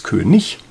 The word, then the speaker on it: König: Und standardsprachlich ist es gar nicht König, sondern es ist König. Also genau das gleiche Ich am Ende wie bei hässlich.